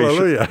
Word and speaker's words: Alleluia: Not only do we provide a platform for local artists, but it's international cooperation to bring choirs from, they always sing Alleluia the same. – Oh, yeah, right. – Either in Japan or Mongolia. – It's all pronounced the same way. – Alleluia.